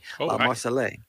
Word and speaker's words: Marseillaise: How do you say it? Marseillaise. – La Marseillaise. All right.